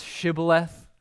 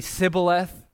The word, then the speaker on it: Shibboleth: And have you ever read this in the book of Judges, chapter 12, where the Ephraimites could not pronounce Shibboleth? They can only say Sibboleth.